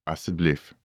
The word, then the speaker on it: asseblief: In Afrikaans, please is asseblief. Let's break it down by syllable. A, s, Asseblief.